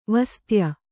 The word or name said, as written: ವಾಸತೇಯಿ